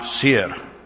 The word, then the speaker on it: Seir: That's S-E-I-R. So I did that thing where you look up, you type in the word and you put pronunciation and it shows you a little recording, and they were pronouncing it Seir. So it's easier for me to say Seir, right?